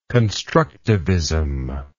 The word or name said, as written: constructivist